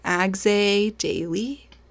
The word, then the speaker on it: Agze Daily: We pronounce it Agze Daily. It's the English transcription of a Pashto word meaning thorn, and Pashto is a language spoken in Afghanistan. There is a story behind that, that's for another day, but that is the pronunciation that we have found most commonly used and easiest for English speakers.